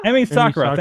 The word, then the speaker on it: Emi Sakura: I want to say Emma Sakura, but I don't think that's right. – Emi Sakura.